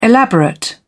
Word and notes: elaborate: British English

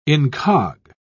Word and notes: incog: British English

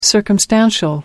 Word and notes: circumstantial: American English

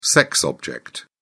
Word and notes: sex object: British English

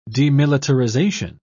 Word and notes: demilitarisation: British English

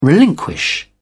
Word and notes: relinquish: British English

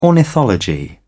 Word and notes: ornithology: British English

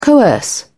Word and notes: coerce: British English

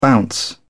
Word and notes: bounce: British English

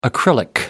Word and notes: acrylic: American English

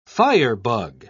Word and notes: firebug: British English

